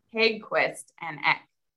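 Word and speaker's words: Haeggquist & Eck: So how do you say it? – Haeggquist and Eck. – Haeggquist and Eck. All right, that's I mean that that's okay. I got it's that. Pretty phonetic.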